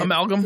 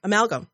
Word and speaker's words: amalgam: And then a dental alma. I don't know how to say it. – Amalgam. – Yeah. Amalgam. Yes. Thank you.